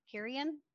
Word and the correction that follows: Corinne: I think it's Corinne. – Corinne. I'm sorry. I don't know why I want to put a Minnesota extra syllable in there.